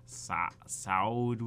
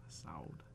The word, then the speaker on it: Saad: And that's how Ryan T. Stallman pronounces Saud, which is spelled S-A-A-D. – S-A-A-D. Nice. – Saud.